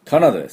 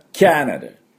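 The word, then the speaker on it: Canada: はい、で次はですね、次に 4 位に人気のあるのはカナダです。カナダ、これも、多少ネイティブの発音と違います。まあカナダって言って通じないことはないでしょうけども、実際はキャナダ、キャナダっていうふうに発音します。キャ、 CA のところがですね、おミャーの音なんですね。でキャナダで、そこにアクセントがあります。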